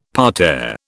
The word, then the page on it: parterre: British English